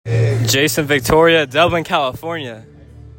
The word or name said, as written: Jayson Victoria